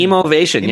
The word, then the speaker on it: Emovation: Let me move on to the fact that you're the founder of the Emotion. Did I say that right? Emovation? Emovation. – Emovation. Yeah, Emovation, you nailed it.